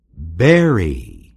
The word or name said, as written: bury